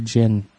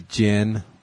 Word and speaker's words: djinn: Like, like a genie djinn. D-djinn. – Oh, okay. – Where the D is silent.